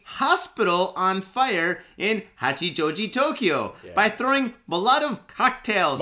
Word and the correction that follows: Molotov: Did I say it wrong? – Yeah. – Oh, you got the German pronunciation. – Molotov.